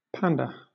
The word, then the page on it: panda: British English